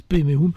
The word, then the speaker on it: Padme Hum: Padme Hum, ça s'écrit comme ça, mais les Tibétains disent Peme Hum.